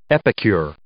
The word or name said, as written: epicure